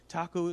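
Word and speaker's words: THAC0: It's taco. You just pronounce that taco.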